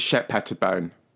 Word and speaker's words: Shep Pettibone: So the way his name is pronounced is Shep Pettibone.